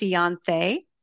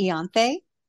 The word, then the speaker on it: Ianthe: So my name is pronounced Iantha, like Beyonce, Iantha.